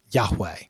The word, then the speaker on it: Yahweh: Several early Greek writers of the Christian church testify that the name was pronounced Yahweh.